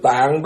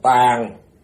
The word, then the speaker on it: tàng: Mà thật chất thì chữ tạng đúng ra nên đọc là tàng. Bởi vì chữ tàng có nghĩa là cái kho chứa đựng, cái kho.